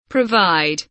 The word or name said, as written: provide